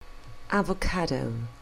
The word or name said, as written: avocado